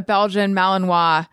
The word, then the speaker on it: Belgian Malinois: So on TikTok a lot, it's like, there's a lot of stuff about like, you should not own a Belgian Malinois.